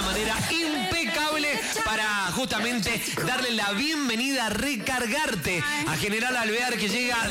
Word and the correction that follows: Recargate: Recargarte, lo dije bien. Creo que lo había dicho mal. Recargarte. O capaz que lo pronuncié mal. – No, lo está diciendo mal. Es recargate.